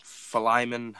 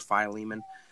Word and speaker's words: Philemon: So Philemon, however you want to pronounce it, or Philemon.